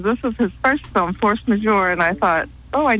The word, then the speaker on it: Force Majeure: Force Majeure, did I pronounce that correctly? – Yes, Force Majeure.